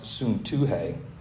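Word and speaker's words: Syntyche: I entreat Euodia, and I entreat, that's pronounced Suntuhe, to agree in the Lord.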